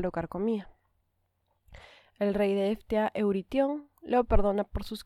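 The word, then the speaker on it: Ftía: Se escribe F-T-I-A, ¿Cómo se pronuncia eso? Eftia. Eftia, vamos a decirle.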